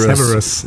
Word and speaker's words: Severus: Heißt der Several oder Severus? Severus. Se also Severus. – Ich habe also ne? ich habe immer, wenn ich die Filme gesehen habe, hab so wie schreibt man denn den Namen? Die nuscheln immer. – Severus. Severus. Severus. Also Se also geschrieben Severus genau.